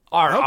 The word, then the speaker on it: RRR: I am saying RRR. Because that's how you say it as a New Zealander. RRR.